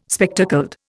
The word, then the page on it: spectacled: Indian